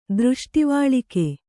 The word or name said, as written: ದೃಷ್ಟಿವಾಳಿಕೆ